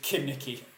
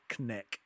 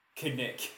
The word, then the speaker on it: Knick: Just Kinnicky. That's what it's written across his screen. No, it's just no, it's just called Knick. – Knick. – Knick. Because you need short syllables.